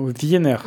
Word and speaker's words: Wiener: Wiener. – Wiener. – Je dis à l'allemand, mais. – Oui, c'est très bien.